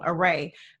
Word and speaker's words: Array: And then second, Arrow, you know, her production company. – Right. – And I said it wrong, Array.